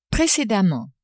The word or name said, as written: precedemment